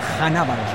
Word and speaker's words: Hannibal: Una falta en medio campo sobre Aníbal Mejbri, que nos comentaba antes de la transmisión, ¿cuál es la forma correcta de pronunciar el nombre de el camiseta 10 de Túnez? – Es Hannibal. – Hannibal, perfecto. – Hannibal Mejbri. – Que se termina escribiendo como tal cual, como Hannibal.